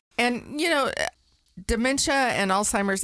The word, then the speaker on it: Alzheimer's: So some people say Alzheimer's, and some people say it's Alt-timers, and some people say it's Old-timers, but it's ALZ.